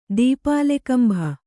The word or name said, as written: ದೀಪಾಲೆಕಂಭ